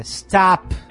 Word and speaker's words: stop: O único som que geralmente as pessoas pronunciam correto nessa palavra é o T, porque o S a gente coloca uma vogal no começo. E stop. Exatamente. A gente coloca uma vogal no final. E esse O, na verdade, não é o mesmo O que a gente pronuncia. – É, não é O o. por exemplo. – Exatamente, exatamente. É stop. – Exatamente. – É, é, um O muito mais aberto, né?